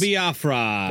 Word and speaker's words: Jello Biafra: Jello Biafra.